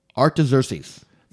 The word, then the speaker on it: Artaxerxes: It's King Artaxerxes.